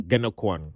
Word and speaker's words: Ganokwan: Sorry, it's like Ganokwan. – Ganokwan. – Yeah. – Yeah.